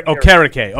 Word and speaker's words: Okereke: Okereke.